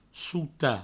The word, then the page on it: sotar: souta